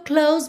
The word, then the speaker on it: close: So close, close, o som termina no S, esse S tem um som de Z, mas o E não é pronunciado, ok?